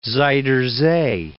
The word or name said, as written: Zuider Zee